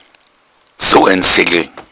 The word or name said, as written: seggl